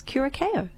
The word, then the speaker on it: Curaçao: However, the drink, blue Curaçao, is sometimes pronounced as Curaçao. So there you go.